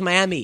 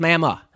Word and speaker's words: Miami: I thought it was Miami. It's Miami. – Of course it is, because it's right, because they're country. What are you gonna do? They pronounce things weird.